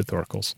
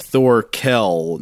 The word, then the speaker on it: Thorkel: Uh, so it's like Sar Do, no Mister accent on the Do. Here we get Thor Kel, no Mister. It's Doctor accent on the Kel. Doctor Thor Kel.